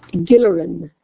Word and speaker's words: Gilleran: Gilleran.